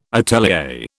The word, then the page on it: atelier: British English